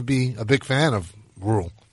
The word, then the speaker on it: Rhule: And I'm pretty sure his name is actually pronounced Rhule.